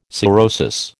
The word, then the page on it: cirrhosis: American English